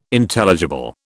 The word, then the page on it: Intelligible: British English